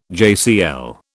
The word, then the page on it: JCL: British English